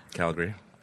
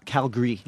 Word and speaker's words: Calgary: How do you pronounce it? – Calgary. – Calgary. – Yeah, we get rid of the A. – Calgary. – It's too Canadian. The A is too Canadian. – Yeah. Calgary. – Calgary. – C-L-G-E-H-Y.